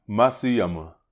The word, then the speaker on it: Hideki Matsuyama: Hideki Matsuyama. – That was Matsuyama. – Matsuyama.